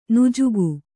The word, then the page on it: ನುಜುಗು: nujugu